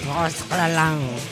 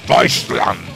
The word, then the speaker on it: Deutschland: Deutschland. – Tienes que pensar en alemanes. Deutschland, ¿eh? – Sí, sí.